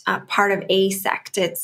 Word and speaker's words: AASECT: And I did say it right, A-A-S-E-C-T. It's an acronym.